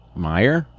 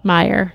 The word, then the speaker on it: Meijer: Meijer? – Meijer.